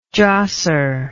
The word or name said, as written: josser